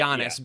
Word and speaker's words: Giannis: You gave it the hard G, and it doesn't have it. It's right, Giannis.